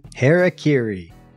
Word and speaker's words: Harakiri: Harakiri.